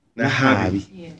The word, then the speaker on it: Nahabi: And today we have a very important guest, a lady in our midst, Madam Sarah Nabahi. I pronounce it well? – I can pronounce it. – Nahabi. Oh, sorry, I said it the other way around.